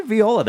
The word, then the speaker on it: viola: Okay, and over here on viola, we have, I'm pronouncing it incorrectly. Am I viola? – Viola. – Like Viola Davis?